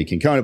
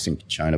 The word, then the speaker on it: cinchona: So it can be cinchona bark, cinchona bark, cinchona bark. The CH and the C, depending on which country you're in and which vowel it follows, all sort of change.